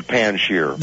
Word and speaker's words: Panjshir: The Panjshir.